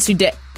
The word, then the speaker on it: Sudeikis: Her ex, Jason Sudeikis? Did I say that right? – Yeah, you got it.